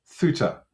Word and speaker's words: Ceuta: It's called Ceuta. Ceuta. It's spelt, let me get this right, C-E-U-T-A. C-E-U-T-A, but it's pronounced Ceuta in Spanish.